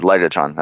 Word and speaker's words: Legatron: His nickname is Legatron. That's how you pronounce it.